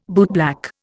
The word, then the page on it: bootblack: Indian